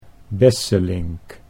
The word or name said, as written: Besselink